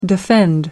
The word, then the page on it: defend: American English